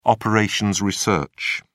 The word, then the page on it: operations research: British English